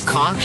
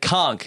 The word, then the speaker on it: conch: Dead conch. And now it's not, so it's not, so he's right. It's not conch, it's conch. – It's conch. – Conch.